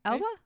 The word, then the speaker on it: Elba: With Idris... Elba? – Hey, you said that was a very good pronunciation.